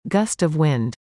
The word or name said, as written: Gust of wind